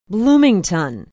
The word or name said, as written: bloomington